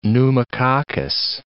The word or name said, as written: pneumococcus